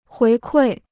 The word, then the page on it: 回馈: huí kuì